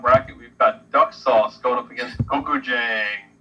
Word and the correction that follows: Gochujang: Listen, Gochujang. – Duck sauce. – Gochujang. Yeah, like what I. That's what I said. – Mark, pronounce that for us, would you? – Gochujang.